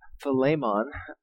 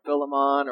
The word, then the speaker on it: Philemon: So if you're ever wondering how to pronounce this, technically in the Greek, it's Philemon, right? But don't worry, I'm going to probably say Philemon or Philemon or whatever during this whole time. But don't worry if you can't get this correctly, all right? But Philemon is technically the correct pronunciation.